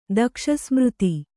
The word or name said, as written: ದಕ್ಷಸ್ಮೃತಿ